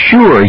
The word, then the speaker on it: sure: I'm sure you shouldn't shoot the shotgun. Yes, that's right. Sure is pronounced with the sh, even though it's spelled with the letter S and not with SH.